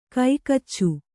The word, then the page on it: ಕೈಕೋಚು: Kannada